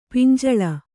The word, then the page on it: ಪಿಂಜಲ: pinjala